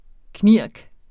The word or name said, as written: knirk